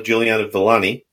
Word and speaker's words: Juliano-Villani: I'm really happy today to have Gabriella Juliano-Villani.